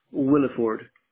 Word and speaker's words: Williford: And, Wesley, your name, you spell it W-E-S-L-E-Y? – That's right. – And what's your last name? – Williford. W-I-L-L-I. Yeah, W-I-L-L-I-F-O-R-D.